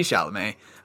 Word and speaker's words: Chalamet: is this Clamomet? – Clamomet, is that how you pronounce Clamomet. It? Clamomet. Timothy Clamomet. No, it's Timothy Chalamet.